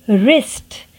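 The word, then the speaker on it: wrist: रिस्ट W R I S T रिस्ट यानी आपकी कलाई रिस्ट डब्ल्यू इज साइलेंट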